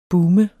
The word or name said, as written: boom